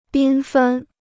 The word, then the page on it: 缤纷: bīn fēn